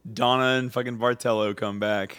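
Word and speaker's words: Barthello: I'm gonna say it with a decently hard T. Bartello. – You're gonna do it's Sicilian pronunciation.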